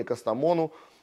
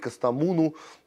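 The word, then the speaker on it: Kastamonu: И в это время э, завершилось собственное роуд-шоу компании «Костомону», не говорить Костомуну, надо говорить правильно название производителей и тех, кто нас кормит, э, в ходе которого производитель знакомил с новинками своими.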